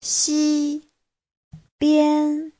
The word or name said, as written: xi1bian1